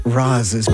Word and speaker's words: Reese's: Anyway, so it is Reese's. Don't be fools, people. – Yeah. – Oh, no. – Say it again. – Roz's peanut butter cup.